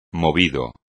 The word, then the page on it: movido: espagnol